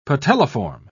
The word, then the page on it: patelliform: British English